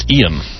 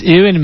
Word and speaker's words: Ewan: And actually, I think even though it's spelled Ewan, it should be pronounced Ian. – It's Ewan McGregor.